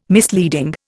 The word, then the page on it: misleading: Indian